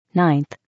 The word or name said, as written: 9th